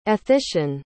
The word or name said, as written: ethician